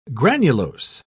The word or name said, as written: granulose